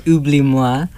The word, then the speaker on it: Oublie-moi: She gave me a great amount of music to work with, so the correct pronunciation of that song is Oublie-moi.